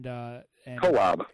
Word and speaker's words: Kolob: And then we went up to, and I always mispronounce this, Kolob Canyons. And Kolob. – Kolob. See, I always mispronounce it.